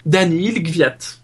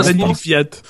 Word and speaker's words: Daniil Kvyat: Et il sera donc accompagné par le russe Daniil Gvyat. – Bravo, bonne prononciation. – Ouais, c'était très bien dit.